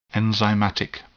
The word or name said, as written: enzymatic